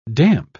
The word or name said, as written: damp